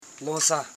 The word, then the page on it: lonsa: lon.sa; Koro